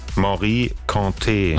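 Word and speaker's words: Mory Kanté: Ich weiß gar nicht, ob ich das richtig ausgesprochen. Ich hoffe ja. Nicht, dass es peinlich ist, muss ich das einfach nochmal checken. Was sagt denn unsere Aussprachedatenbank? Wir haben ja hier so eine tolle Datenbank. So, hier. Wie wird's ausgesprochen? – Morikante. – Ach so, guck mal. Es war also falsch. Morikante, Jeke, Jeke. Alles klar.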